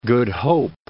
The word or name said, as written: Good Hope, Cape of